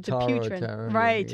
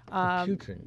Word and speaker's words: Dupuytren: It's Dupren? – Well, I guess it depends on who you ask. I always said Dupuytren. Exactly. Yeah. I always said Dupuytren. – Dupuytren.